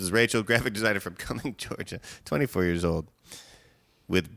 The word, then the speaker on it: Cumming: It's C-U-M-M-I-N-G. – How would you pronounce that? – Say it one more time. – C-U-M-M-I-N-G. – Cumming. – Yep, that's how I pronounce it too.